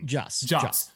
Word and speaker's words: Joss: Is it Joss or Joss Whedon? – Joss.